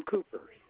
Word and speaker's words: Cooper: Last name Cooper, COOPER.